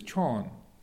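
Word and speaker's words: chon (ช้อน): So Ajahn Jayasaro's name was his lay name was Sean, and uh, so the the Thai word for a spoon is chon. And the Lao pronunciation, the ch becomes a s sound.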